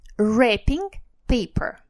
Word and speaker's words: wrapping: Wrapping paper. Обратите внимание на слово wrapping. Оно будет писаться с буковкой W впереди, которая не произносится.